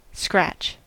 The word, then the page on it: scratch: [skɹætʃ]; /skrætʃ/